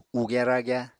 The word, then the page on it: ugya-ragya: Apatani